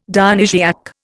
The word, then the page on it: Dionysiac: Indian